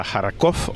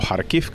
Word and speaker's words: Kharkiv: De Zaporilla me subo a otro tren hacia Kharkov o Kharkiv, que es la pronunciación ucraniana, y ahí me encuentro la ciudad seguramente, bueno, sin seguramente, más castigada de la guerra.